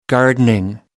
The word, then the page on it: gardening: American English